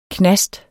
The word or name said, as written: knast